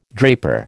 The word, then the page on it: draper: American English